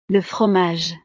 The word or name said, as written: le fromage